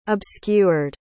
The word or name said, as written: obscured